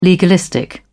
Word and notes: legalistic: British English

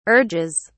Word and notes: urges: British English

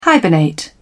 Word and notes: hibernate: British English

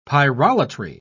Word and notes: pyrolatry: British English

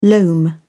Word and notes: loam: British English

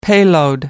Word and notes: payload: American English

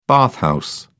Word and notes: bathhouse: British English

